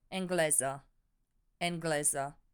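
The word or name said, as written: engleză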